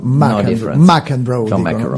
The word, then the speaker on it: McEnroe: John McEnroe a Cipicchia, o McEnroe, perché qui la storia pure si divide, come lo pronunciamo? – Io direi che John McEnroe, dicono quelli americani.